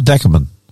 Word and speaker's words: Dakabin: Dakabin or? Yeah, Dacabin. Dakabin? No, Dakabin. Good. Dakabin. That's from a Queensland boy. It's Dakabin.